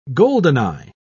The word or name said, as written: goldeneye